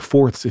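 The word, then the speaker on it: forte: which I would say is one of Gilbert's fortes, or forts if you prefer the original French pronunciation.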